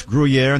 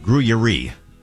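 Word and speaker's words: Gruyère: Yeah, it's Gruyere, not Gruyere.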